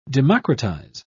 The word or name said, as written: democratize